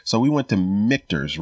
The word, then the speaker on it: Michter's: He's like, oh, it's Michter's, like Victor's.